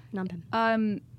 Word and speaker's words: Phnom Penh: Phnom Penh, but... – That's right, Phnom Penh.